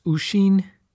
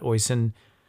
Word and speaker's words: Oisin: It's actually probably pronounced Ushin, but I'm not entirely sure, so I'm going to use this like Americanized version of that, Oisin.